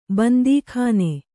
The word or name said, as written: ಬಂದೀಖಾನೆ